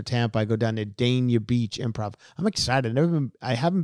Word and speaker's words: Dania Beach: You actually have the pronunciation right on the, what is it? Go ahead. – Dania Beach. – Dania Beach. Says he got it from the mayor of Dania Beach.